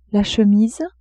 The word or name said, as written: chemise